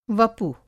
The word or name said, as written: वपु